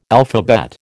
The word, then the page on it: alphabet: American English